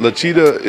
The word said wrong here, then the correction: Machida: is, did I say his name right? Lachita? Because he run all the time. Because he run all the time, Lachita. Machida.